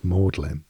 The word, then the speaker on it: Magdalene: Magdalene is pronounced Maudlin.